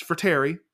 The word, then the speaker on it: Frattare: It's Frattare. I mispronounced it last time.